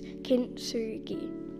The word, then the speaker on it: kintsugi: Kintsugi is a Japanese word, and you might say it like this, kintsugi.